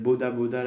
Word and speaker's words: boda boda: Donc je prenais le moto-taxi, qui, euh, en petite anecdote, il l'appelle Boda Boda là-bas, qui est la, à la prononciation euh, locale, l'équivalent de border border.